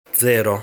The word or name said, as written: Zero